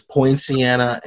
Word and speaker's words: Poinciana: Yeah, I think you're mixing two different, like, they're like right there, but I think you're mixing the two names together. I think it's Poinciana and Panacea. Those are the two places.